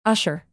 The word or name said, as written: usher